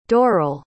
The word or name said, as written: dorrel